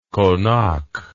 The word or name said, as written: konak